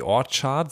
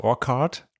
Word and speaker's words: The Orchard: Selçuk Erdoğan, du bist Label Manager bei The Orchard, so spricht man es richtig aus, nicht Orchard? – Korrekt.